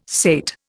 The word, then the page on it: sate: Indian